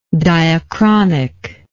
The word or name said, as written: diachronicness